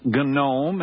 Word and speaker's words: gnome: It's spelled G-N-O-M-E. And some says it's gnome, and some say it's gnome, and the G is silent.